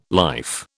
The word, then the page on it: life: British English